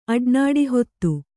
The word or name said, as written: ಅಡ್ನಾಡಿಹೊತ್ತು